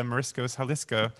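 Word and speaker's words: Mariscos Jalisco: And you know, if I have it on my coffee table, so it's like, oh yeah, I have to go to Marisco's Jalisco. Did I say that right? – Yeah, you did.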